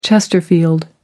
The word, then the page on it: chesterfield: British English